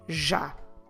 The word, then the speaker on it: já: The first word I want to tell you about is the word já. Já. Como se escreve já? J-A com acento. Já.